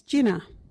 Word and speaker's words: jina: Our local Wadjuti word is jinna. What is jinna? Jinna is your foot. You can use jinna in this sentence. Bye, you got a big jinna. Or you got a sore jinna. Jinna is spelt J-I-N-A.